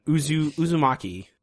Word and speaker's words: Uzumaki: We are going to read, pardon my mispronunciation, Uzumaki, something along those lines. – That's close. That's good enough.